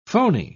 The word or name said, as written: phoney